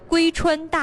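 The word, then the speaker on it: 妫川: 先说一下这个龟吧，这个龟字呢是一个女加一个为什么的为，很多朋友呢一看到这个字呢也知道它是属于延庆的，很多朋友就把这个字自以为的读成为，其实它是一个延庆一个独有的字，叫做龟川大地。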